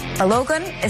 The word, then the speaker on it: Balogun: still won 2-0, but Balogun is suspended for the next match. Maybe you can tell me how it's pronounced. – Balogun. You basically got it right.